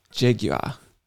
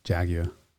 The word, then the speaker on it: Jaguar: Instead of Jaguar. – It's not how it's pronounced. Jaguar. – Well, I mean, it's three syllables, right?